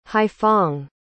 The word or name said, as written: haiphong